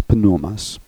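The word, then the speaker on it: Pneumas (Πνεῦμα): In Greek, there's a Hebrew equivalent to, in Greek, the name Holy Spirit, Hagias Pneumas, okay? In Greek, the P would be pronounced in, we would say, pneumatic, pneumonia. In Greek, the P is pronounced, so it's Hagias Pneumas.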